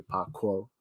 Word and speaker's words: Paakow: There should be more emphasis on the K, so it should be Paakow.